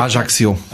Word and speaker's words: Ajaccio: Ajaccio auf Korsika oder Ajaccio, glaube ich, spricht man das aus, äh, ganz korrekt. Äh, Civitavecchia. – Ajaccio. Ajaccio, okay.